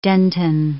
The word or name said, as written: dentin